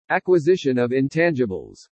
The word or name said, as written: ACQUISITION OF INTANGIBLES